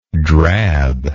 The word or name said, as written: drabness